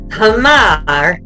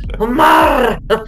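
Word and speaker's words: Humar (حمار): And he was like, Humar, with this British accent. And it was hilarious. I'm like, no, it's not like that. It's Humar, roll your R, dude.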